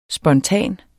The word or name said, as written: spontan